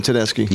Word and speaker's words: Tedeschi: Susan Tedeschi.